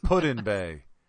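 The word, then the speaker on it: Put-in-Bay: That's not how they pronounce it. They pronounce it the way Sherry pronounces roof. It's Putin Bay. Like, that's where you go put in your boat.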